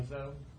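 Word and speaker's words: Handzo: Sir, what was your last name again? – Hanzo. H-A-N-D-Z-O. I learned how to spell it.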